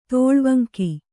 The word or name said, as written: ತೋಳ್ವಂಕಿ